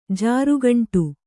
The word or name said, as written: ಜಾರುಗುಂಡಿ